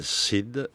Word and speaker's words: Cyd: Alors là, nous sommes dans Femmes, avec un personnage important du livre, qui s'appelle Cyd, qui s'écrit C-Y-D, comme la danseuse très célèbre qui s'appelait Cyd Charisse, dont les jambes sont encore dans les mémoires attentives.